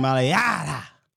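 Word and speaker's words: Mailata: That's how we're gonna pronounce mulatto, whatever the hell it is. Mulatto, culatto. – Oh, Jordan Maliata.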